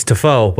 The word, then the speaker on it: Tiafoe: That's not how you say it. – Whatever. I watched him his last round. He looked there awesome. – No, there's... I don't... It's Tiafoe, but... – There's no N. – No, there's not. – Tiafoe. – I don't know why I said it like that.